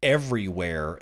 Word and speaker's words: Everyware: So the asset monitoring company Everywhere, and that's spelled E-V-E-R-Y-W-A-R-E, Everywhere, get it?